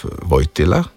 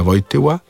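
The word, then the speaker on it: Wojtyła: Tu moramo vseeno izpostaviti, da je ta Vojtila, da smo še bolj pravilnejši, je ta Vojtila v slovenščini ne sistemsko izgovorjen. Ker slovenščina ima L-je, če tu govorimo, da je ta L vseeno fonem L, mi izgovarjamo pred samoglasniki kot L, torej kot dlesnični glas.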